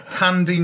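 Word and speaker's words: Thandie: No, it's Thandi Newton.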